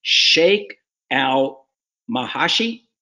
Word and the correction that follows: Sheikh al-Mahshi: Meshi. Yeah. That's a favorite. That's my favorite. It's called Sheikh al-Mahshi. – I knew I mispronounced it here.